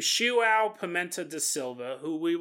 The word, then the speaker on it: João: It's spelled J-O-A-O. Google told me it was pronounced João, so that's what I was going with.